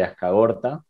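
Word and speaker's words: Azkargorta: Ahí era la figura de la Bolivia de Ascagorta, se pronuncia así, correcto, que fue al Mundial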